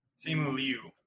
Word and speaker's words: Simu Liu: Simu Lee or Lean? – Simu Lee. He's awesome. Crazy Rich Asians is great. But he was upset when Simu Liu. That's what it is.